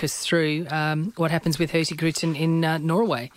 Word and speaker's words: Hurtigruten: Hurtigruten, yes.